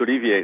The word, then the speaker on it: Olivier: It's not like Toussaint, but it's Olivier.